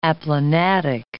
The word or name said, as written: aplanatic